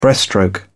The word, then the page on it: breaststroke: British English